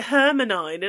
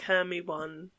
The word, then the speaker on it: Hermione: I used to pronounce it Hermanine, and I remember someone else telling me it was like Hermie One.